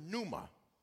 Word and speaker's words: pneuma: But then in the New Testament, it's the word pneuma. I'm going to spell it for you because I see somebody writing N-O-O-M-A. It is P-N-E-U-M-A. P-N-E-U-M-A for my note takers.